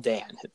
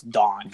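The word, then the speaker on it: Daan: It's spelled Dan, but it's Don. – What? – Dutch. Because he's Dutch.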